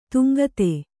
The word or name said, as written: ತುಂಗತೆ